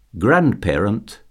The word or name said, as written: grandparent